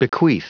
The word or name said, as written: bequeath